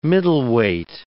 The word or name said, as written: middleweight